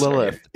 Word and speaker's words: Lilith: Yeah, Quinn and Severo, they bring back Lilith. Am I pronouncing that right? You're the audiobook listener. – It is Lilith.